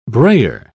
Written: brayer

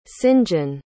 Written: sinjin

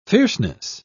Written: fierceness

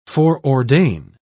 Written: foreordain

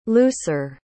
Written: looser

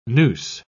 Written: nous

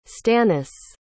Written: stannis

